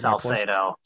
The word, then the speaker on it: Salcedo: How do you say your last name? Descalzo or something like that? – Salcedo. – Salcedo. Salcedo. Okay. I was not close.